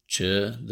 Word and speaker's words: Chöd: Chö is spelled C-H-O-D with an umlaut, that's the two little dots over the O. And the D is silent. Welcome to the wonderful world of Tibetan grammar.